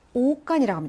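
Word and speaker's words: 오간이라고 (окон): 자, 아크노의 복수 생격은 이런 형태가 되는데 강세가 앞으로 이동해서 오간이라고 합니다.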